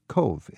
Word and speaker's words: Cobh: And that town, it's a little confusing because it's spelled C-O-B-H. – Yes. – And it's spelled like Cove. – Cove, C-O-V-E, yeah, like a cove.